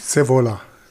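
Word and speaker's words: Scaevola: Mutius Saviola, richtig? Sevola. Sevola.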